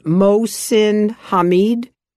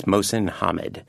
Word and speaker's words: Mohsin Hamid: now, tell me if I pronounce his name wrong. It is Mohsin Hamid. – I think it's Mohsin Hamid. – Hamid. – Because I listened to the audio of this, and that's how he pronounced his name.